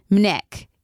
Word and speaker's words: MNEK: And in this particular episode, there was an artist by the name of, I'm going to get it wrong, I think it's called Mnek. It's spelled M-N-E-K.